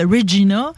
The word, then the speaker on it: Regina: de la prononciation de Regina qui sonne comme Vagina.